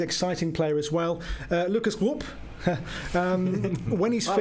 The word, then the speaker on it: Rupp: Rupp. So think Roy Hodgson and you'll pronounce it right.